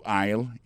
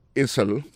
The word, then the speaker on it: aisle: Go to aisle 11 and you will find it. Aisle là, c'est A-I-S-L-E. Vous prononcez aisle. Il marque Essel.